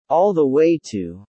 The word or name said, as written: all the way to